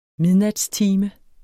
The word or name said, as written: midnatstime